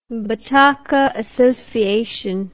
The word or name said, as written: Bataka Association